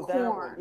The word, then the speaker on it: Quorn: Oh, oh, corn. yeah. – Corn, corn. Corn. corn. – It's corn. corn. They pronounce it corn. Why it's spelled that, I don't know.